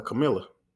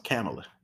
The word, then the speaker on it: Kamala: I was about to call her Camilla. So I heard that her name is pronounced Kamala.